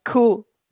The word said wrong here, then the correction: coup: Military coup.